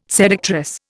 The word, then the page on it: seductress: Indian